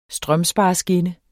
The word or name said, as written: strømspareskinne